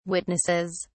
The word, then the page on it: witnesses: British English